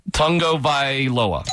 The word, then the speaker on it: Tagovailoa: N, but there is an N pronunciation in his name. – Tagovailoa.